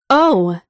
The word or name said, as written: oh